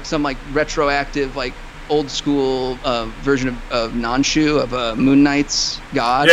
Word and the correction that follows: Khonshu: I think it's K-H. I've always pronounced Khonshu.